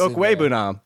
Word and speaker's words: Okwuebunam: Okwuebunam. – Okwuebunam. I don't think the ooh is Okwuebunam. It's only four syllables.